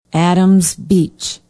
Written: adams beach

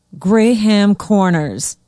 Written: graham corners

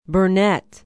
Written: Burnett

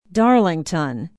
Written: darlington

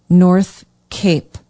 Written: north cape

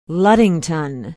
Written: ludington

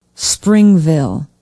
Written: springville

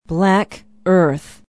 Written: black earth

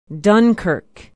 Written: dunkirk

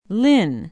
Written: lynn

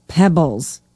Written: peebles

